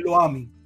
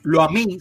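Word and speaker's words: Lo-ammi: Pasa que como no tiene acento en la última I, es lo ami, lo mí. porque si lo a mí sería si tuviera el acento en la última, por eso lo menciono así, lo pronuncio.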